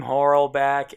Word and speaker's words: Horlbeck: It's Craig spelled normally, C-R-A-I-G. Last name, Horlbeck, H-O-R-L-B as in boy, E-C-K.